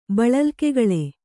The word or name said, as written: ಬಳಲ್ಕೆಗಳೆ